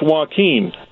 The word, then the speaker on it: Joaquin: Sir, I believe it's pronounced Joaquin.